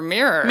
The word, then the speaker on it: Mirer: It's M-I-R-E-R. – Sure, Mirer. – Mirer. That's how I'm going to pronounce it.